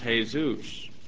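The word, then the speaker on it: Jesús: There are still boys in Mexico named Jesus, pronounced Jesus. Jesus is the name of a man.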